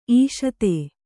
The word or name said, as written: ಇಷುಧಿ